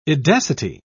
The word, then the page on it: edacity: British English